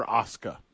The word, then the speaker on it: Asuka: And Van, Tampa is ready for Asuka.